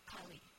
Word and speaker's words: Kali: Am I pronouncing that right? I've heard it Kali. Kali. – I've heard it as Kali. – Kali. Okay, Kali. So Kali is the goddess of destruction.